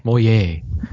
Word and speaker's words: Moyet: Moyet. – Yeah. – The T is silent.